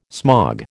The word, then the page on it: smog: American English